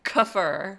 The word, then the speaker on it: kefir: See, I just called it what you call it, which is hysterical because I say it wrong as well. Kefir. – Is it kefir? – Because I used to say it kefir. You say it kefir. – Tomato, but tomato. – It's really kefir.